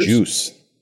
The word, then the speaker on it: Joos: J-O-O-S. It's my last name. Yeah. – Oh, yeah, that's your last name, Juice. – Yeah. – Is it pronounced Juice or is it Juice? – It's juice, like actual juice.